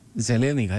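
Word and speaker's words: Zelenika: Ζελένικα, έτσι προφέρεται, ναι.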